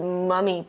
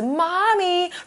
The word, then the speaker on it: mummy: 同学们发音要注意哦，是 mummy 不是 mommy，如果是 mommy 那就是你的妈妈咯。